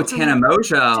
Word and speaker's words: Tana Mongeau: I like pulled out some names like Tana Mongeau. Am I pronouncing that right? – Oh, Tana Mongeau.